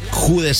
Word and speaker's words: Jude: A ellos se le suman 199 personas llamadas Jad, se pronunciaría Jude, se escribe Jad, creo que se pronunciaría, y dos registradas como Bellingham como nombre de pila, dos personas.